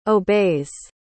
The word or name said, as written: obeys